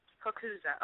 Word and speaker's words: Coccuza: My last name is pronounced Cocuzzo. Mm-hmm. – Cocuzzo. Okay. – Cocuzzo. Yep. You got it right.